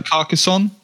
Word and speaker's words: Carcassonne: I can't pronounce that one. Car Sassozone? Calzone? Car Sassozone? I don't know what it is. – I can't see it. – Carcassonne? – Yes. – Carcassonne? That might have been it.